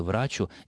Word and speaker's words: врачу: то в конструкции «врачу исцелися сам» ударение в слове «врачу» используется только на первом слоге.